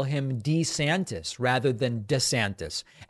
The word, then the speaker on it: DeSantis: Now, one other just like kind of funny thing, you might have noticed that in that ad, Ron DeSantis pronounced his name DeSantis. This is like a kind of funny thing. Ron DeSantis in older videos used to pronounce his last name DeSantis. And then more recently, while he's been governor, it's been DeSantis. And then in that video, he went back to DeSantis, and it's all kind of weird.